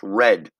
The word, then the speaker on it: read: Read and read, but read in the past is pronounced red, R E A D.